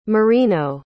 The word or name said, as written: Marino